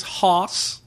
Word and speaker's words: Hoss: This is Haas Oktoberfest Lager. – How do you spell that, Haas? – H-O-S-S.